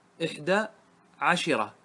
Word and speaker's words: عشرة: والشين فيها عن تميم كسرة، قبيلة تميم يكسرون الشين مع الإناث، فيقولون إحدى عشيرة، عشرة، إحدى عشرة، يكسرون الشين، واضح؟ هذا مع الإناث. أما مع الذكور فالشين فيها تكون مفتوحة كما نطقه الناظم 11 رجلا.